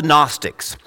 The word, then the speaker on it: Gnostics: Silent G, of course, you can tell that. They're called the Gnostics.